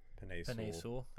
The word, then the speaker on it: Penei Sewell: With I can't say his name, Penei Sewell. – Penei Sewell.